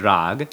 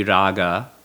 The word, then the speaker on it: raga: in Hindi, the word is rag. – Okay. – In Sanskrit, the word would be raga. It's just the way that Hindi is pronounced in a way. The final short vowel uh is always left off in modern Hindi.